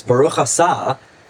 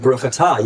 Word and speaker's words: Baruch Atah: You know, the prayers, Baruch Asah, was still his version of Baruch Atah.